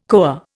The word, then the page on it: koa: Indian